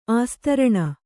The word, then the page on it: ಆಸ್ತರಣ: āstaraṇa